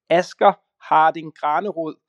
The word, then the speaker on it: Asger Harding Granerud: And yes, my name is, if I pronounce it in Danish without any accent, it would be Asger Harding Granerud. So close enough. But I'm used to the English pronunciation by now.